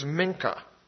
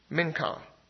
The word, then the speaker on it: minchah: Well, the Hebrew word for offering here is minkah. Minkah. It's spelled M-I-N-C-H-A-H.